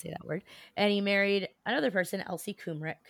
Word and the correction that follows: Krummeck: That's not how you say her name. Krummick. – All right. – Krummeck.